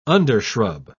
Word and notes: undershrub: British English

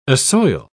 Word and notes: assoil: British English

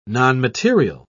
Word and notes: nonmaterial: British English

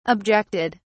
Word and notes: objected: British English

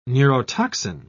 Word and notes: neurotoxin: British English

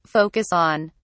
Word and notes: focus on: British English

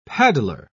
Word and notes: paddler: British English

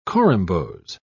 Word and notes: corymbose: British English

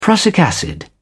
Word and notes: prussic acid: British English

